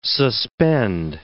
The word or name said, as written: suspend